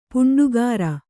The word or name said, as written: ಪುಂಡುಗಾರ